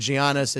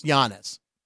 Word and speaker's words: Giannis: So I got lectured when I said Giannis. It's Giannis. – Yes. Yes. Right. Giannis.